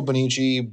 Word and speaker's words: Bonnici: So my name is Joshua Bonici, B as in boy, O-N-N-I-C-I.